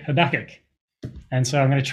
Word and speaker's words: Habakkuk: And so we're going to look at the prophet Habakkuk. Now, the word Habakkuk, or his name Habakkuk, is pronounced quite differently depending on who you ask.